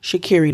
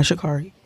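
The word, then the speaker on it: Sha'Carri: that is how you pronounce her name. Shakiri, not Shakari.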